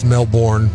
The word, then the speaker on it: Melbourne: Yeah, the idiots down under, they say Melbourne. – Oh. – But in Florida, it's Melbourne. – Yeah, Melbourne. – Like it's spelt.